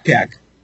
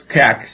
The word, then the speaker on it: Cak: Cak. Yeah, close. Cak. C-A-K.